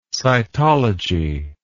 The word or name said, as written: cytology